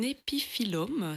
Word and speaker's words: épiphyllum: On a une autre question de Nina qui a un épiphyllum. Est-ce que je le prononce correctement? – C'est juste.